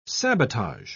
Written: sabotage

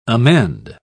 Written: amend

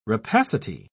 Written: rapacity

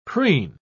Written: preen

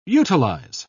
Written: utilize

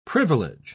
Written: privilege

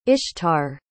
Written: ishtar

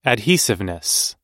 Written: adhesiveness

